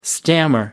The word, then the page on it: stammer: American English